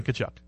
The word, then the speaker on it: Tkachuk: Wait. There's no way he pronounces his name the same way as he does. He pronounces it the same. – But there's a Z in there. – Yeah, well, it's Dan Tkachuk. It's pronounced the exact same.